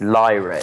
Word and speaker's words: Lyra: You know, I took my eldest one, which is, it's Lyra is the way you pronounce it, like the old string instrument.